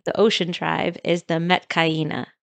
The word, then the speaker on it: Metkayina: Am I saying that right? – It is Metkayina. – Metkayina. – Metkayina. And they're all Na'vi people.